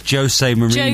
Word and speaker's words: Jose Mourinho: I've said it incorrectly. – Mourinho? – Mourinho. – I think it's Jose Mourinho.